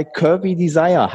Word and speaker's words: Curvy Desire: Jetzt aber, lange Rede, kurzer Sinn, wir sind bei Curvy Desire. Habe ich das eigentlich richtig ausgesprochen? Wird es Englisch ausgesprochen? – Oh ja, richtig, weil manche Leute haben mir immer gefragt, bist du Curvy Desire?